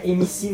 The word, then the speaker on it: MC: Mac Negro do Borel. – Mac Negro do Borel. – Que é MC Negro do Borel. Ele falou Mac Negro do Borel.